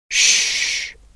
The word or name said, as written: tys